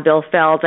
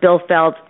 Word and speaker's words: Bilfeld: If you just put my name on it, it'll come up, Pam Bilfeld.